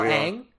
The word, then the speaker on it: Ang: A-N-G. – Oh, just Ang. – Yeah. – Oh, there you go. – I heard more syllables there, so I was way out of that.